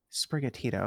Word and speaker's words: Sprigatito: Sprigatito.